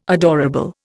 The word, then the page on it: adorable: Indian